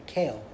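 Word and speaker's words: Kel: Aleph Lamed, Kale, we pronounce it, is Hashem's name.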